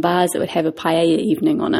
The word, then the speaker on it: paella: Now, a lot of people, when they think of Spain, they think of paella. And paella, which is a delicious rice dish. – Paella, my paella. Parents would say. – Yeah. That double L is supposed to be pronounced like a Y, but, you know, whatever.